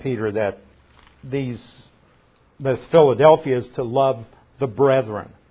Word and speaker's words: Philadelphia: Philadelphia, actually Philadelphia would be the Greek pronunciation.